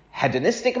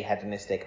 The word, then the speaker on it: hedonistic: And I didn't say this on the tape last week, but I say hedonistic. I'm not mispronouncing it in England. That's how we pronounce it. I know you say hedonistic, but I'm not going to do it.